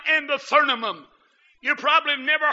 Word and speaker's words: Thummim: But the correct pronunciation is Urim and Thummim.